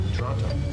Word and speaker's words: Toronto: Toronto. – Please don't pronounce the T's.